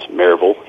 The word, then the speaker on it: Maryville: I am, my official title is Associate Professor of History at Maryville College, which we pronounce Maryville here.